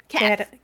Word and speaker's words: CADTH: Because it's C-A-D-T-H. How do you say that? – Cadeth. Cadeth.